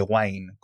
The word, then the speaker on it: Wine: De hecho, usa muchísimas librerías de Wine o de Wine, como queráis pronunciarlo,